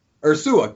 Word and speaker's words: Ursua: How do you say his last name? I have no Ursua, idea. Ursua, yeah. – Ursua, great.